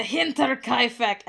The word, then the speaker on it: Hinterkaifeck: That's right, we're doing the Hinterkaifeck, and I will be saying it like that every single time. – That's how it's pronounced.